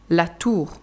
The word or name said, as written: La tour